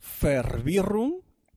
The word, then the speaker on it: Verwirrung: Yo te Y hoy vamos a saber qué significa di con ahí, con acento, bien acento fuerte suizo. Di Verwirrung, que se escribe V-E-R-W-I-R-R-U-N-G, y no es otra cosa que una confusión.